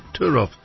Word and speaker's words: Turów: That's the W that's pronounced the V, isn't it? Turów, which produces lignite, or it's also known as brown coal.